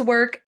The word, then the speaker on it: Werq: So work is spelled W-E-R-Q. I know you're thinking W-O-R-K. That sounds like hard work, but no, we're talking about W-E-R-Q.